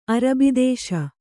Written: ಅರಬಿದೇಶ

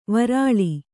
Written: ವಿರಲ

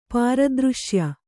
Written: ಪಾರದೃಶ್ಯ

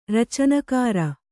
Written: ರಚನಕಾರ

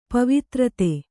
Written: ಪವಿತ್ರತೆ

